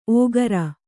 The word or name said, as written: ಓಕರ